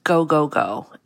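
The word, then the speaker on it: go, go, go (五五五): They're raising about $555 million, which is important only in that in Japanese, the numbers 555 is pronounced go, go, go.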